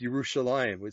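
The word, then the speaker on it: Yerushalayim (ירושלים): it's in the Hebrew is pronounced Yerushalayim. It starts with a Y.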